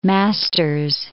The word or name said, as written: Masters